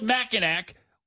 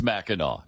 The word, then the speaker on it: Mackinac: Why do they spell it Mackinac when it's Mackinac?